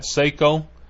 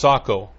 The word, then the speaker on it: Seiko: You know, a lot of people call that Seiko. It's actually not. If you want to say it properly and really impress the hell out of your rich clients, it is Sako. It's not Seiko.